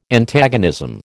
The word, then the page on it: Antagonism: American English